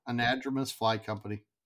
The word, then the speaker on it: Anadromous Fly Company: It's just Anadromous Fly Company, isn't it? Is that what it is? – Yes. Anadromous Fly Company.